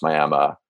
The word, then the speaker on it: Miami: And so basically Roberts County is this one town, Miami, spelled Miami, but I found out pronounced Miami.